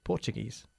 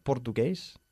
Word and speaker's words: Portuguese: Portuguese. – Portuguese. – Okay. My teacher went to Brazil to learn Portuguese. Okay. Not portugués, sino porcha. Portuguese.